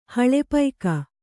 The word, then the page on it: ಹಳೆಪೈಕ: haḷe paika